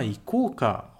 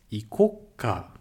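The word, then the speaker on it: icoca (行こうか): É muito comum você escutar ikouka. Icoca, tá com o ts pequeno, tá bom? Então, icoca, na fala, quando a gente fala muito rápido, é comum você escutar sa icoca.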